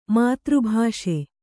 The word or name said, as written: ಮಾತೃಭಾಷೆ